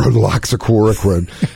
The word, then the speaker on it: hydroxychloroquine: Yeah, hydroxychloroquine.